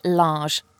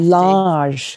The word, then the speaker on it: Lage: But we know that your new man in charge, Bruno Lage, and I'm sure you'll let me know how to pronounce his name properly. – Imagine it's a J. It's Lage. That's all you Lage.